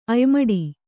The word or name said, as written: ಅಯ್ಮಡಿ